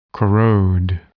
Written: corrode

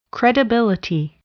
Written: credibility

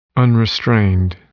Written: unrestrained

